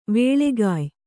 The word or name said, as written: ವೇಳೆಗಾಯ್